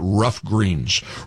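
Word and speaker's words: Ruff Greens: I'm talking, of course, about Rough Greens. Rough Greens is spelled R-U-F-F.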